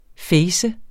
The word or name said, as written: facet